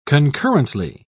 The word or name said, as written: concurrently